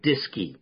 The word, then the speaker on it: DSKY: So they developed something called the display keyboard, which they abbreviated DSKY, and they pronounced it DISKY.